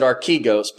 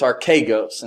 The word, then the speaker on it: archegos: The Greek word is archegos, and I used to pronounce it archegos, but it's archegos, and so I'm going to try to remember that this morning.